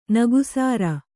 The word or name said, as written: ನಗಸಾರ